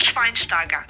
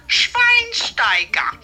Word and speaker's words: Schweinsteiger: Die korrekte Aussprache ist Bastian Schweinsteiger. Ich wiederhole, Schweinsteiger. Sola Barrieta war nicht richtig.